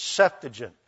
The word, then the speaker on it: Septuagint: By the way, for those of you who find that Septuagint just rolls off of our gringo tongues nicely, you got to get the emphasis on the right syllable It is the first one, and it is Septuagint.